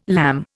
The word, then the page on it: lamb: Indian